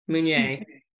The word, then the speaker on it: Meunier: Meunier.